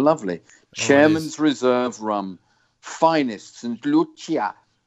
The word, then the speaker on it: St. Lucia: Oh, sorry. Pronunciation correction. It's St. Lucia.